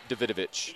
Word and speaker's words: Davidovich: That's a very Americanized pronunciation of that name because it is spelled D-A-V-I-D-O-V-I-C. And you're right, Davidovich, like Lolita Davidovich, the actress.